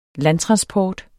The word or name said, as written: landtransport